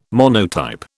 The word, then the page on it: monotype: British English